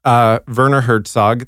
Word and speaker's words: Werner Herzog: And on all of them, along with Werner Herzog, that's how you pronounce it. – it's not Herzlberry.